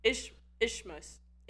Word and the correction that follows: isthmus: I looked up Bible. Isthmus. I was kind of close. Isthmus? Isthmus.